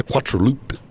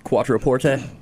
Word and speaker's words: Quattroporte: The Quattroloop. – Quattroporte.